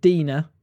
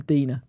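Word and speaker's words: DeNA: They've partnered with a company called DNA, spelt Dina, and it's one of those companies where they need to issue a statement saying how to say their blooming name, because they just bugs me. So I'm going to call them Dina, but they are, yeah, DNA.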